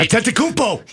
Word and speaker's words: Antetokounmpo: Giannis Antetokounmpo. Did I say that right? – Antetokounmpo.